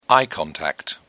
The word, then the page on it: eye contact: British English